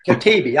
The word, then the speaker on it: Katebi: Katebi.